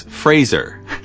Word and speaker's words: Fraser: That's when Fraser corrected him and said, "It's Fraser." This is hard to read. After Sandler repeated it and didn't hit the mark, Fraser pointed out that it rhymes with the word razor.